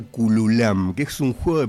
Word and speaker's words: Kulwam: Se escribe K-U-L-W-A-M, Cululam.